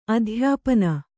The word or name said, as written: ಅಧ್ಯಾಪನ